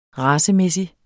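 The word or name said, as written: racemæssig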